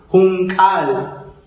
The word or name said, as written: junk'aal